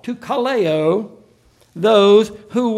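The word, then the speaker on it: kaleo (καλέω): There's a Greek word, kaleo, is the way it's pronounced, that is used again and again in this passage.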